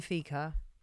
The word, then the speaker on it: fika: That will not be fika.